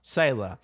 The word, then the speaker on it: Selah: mm How do you say that? Selah, right? It's Selah. Selah.